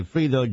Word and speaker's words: Fredo: Yes, it's spelled F-R-E-D-O, which will be Fredo, but we say Frito, John Frito, who is the creator of the Frank Sinatra show.